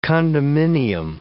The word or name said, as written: condominium